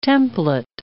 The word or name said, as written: template